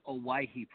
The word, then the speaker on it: Owyhee: So the produce company is Owyhee Produce.